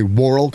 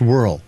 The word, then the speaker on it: whorl: And I say whorl because it has an O in it, but it's still pronounced whorl.